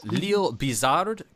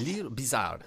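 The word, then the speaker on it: L'Île-Bizard: So because of that, his family moved to, and I'm going to butcher this pronunciation, it's a Lille-Bizard, Quebec, which is very close to Montreal. – Yeah. Yes, you're right. – So there's that Lille-Bizard.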